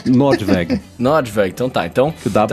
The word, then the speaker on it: Nordweg: É Nordweg que fala, né? Eu tô falando errado. Eu diria, como é alemã, Nordweg é, é o caminho pro norte, o caminho para cima.